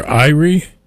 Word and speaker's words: Irie: I-R-I-E is the way you pronounce that. I'm going to say Erie or Irie.